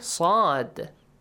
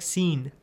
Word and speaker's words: ص: Yeah, the pronunciation of letter Saad. – Saad. Sa, sa, sa, sa, sa, sa. Okay, that's correct. He made a mistake here, yes? How did he pronounce? – Like, it pronounced like seen. – Seen, and also it was light one.